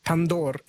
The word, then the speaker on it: tandoor: And I have to pronounce it tandoor in case anyone Indian is listening. They try to correct me on my pronunciation.